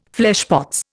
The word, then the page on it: fleshpots: Indian